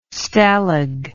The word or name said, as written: stalag